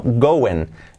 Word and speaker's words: going: Go en No es going, eh, no, going. La I como una E, go en Y la G del final casi no se pronuncia.